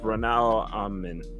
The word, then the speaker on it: Braunau am Inn: I think I said that right. – Braunau am Inn.